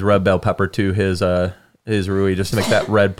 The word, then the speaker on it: rouille: How do you spell that? That was very good. – Yeah, R-O-U-I-L-L-E. – Rui. – Oh, okay. – It's French.